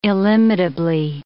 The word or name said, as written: illimitably